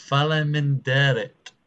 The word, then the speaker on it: Faleminderit: Faleminderit.